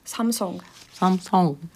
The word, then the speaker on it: Samsung: Samsung. Ar O, jā. – Samsung. – Viņi tā kā... arī visur liek to uburtu, jā. – Bet īstenībā viņi izrunā kā O. Tāds garenāks O.